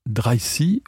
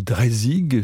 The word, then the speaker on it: Dreisig: Bonjour, je vous propose de faire la connaissance peut-être avec une soprano franco-danoise qui s'appelle Elsa Dreisi ou Dreisig, suivant qu'on prononce en danois en français, elle est la fille de deux artistes lyriques, l'une danoise, l'autre français.